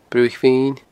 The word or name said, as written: Bruthaich Mhin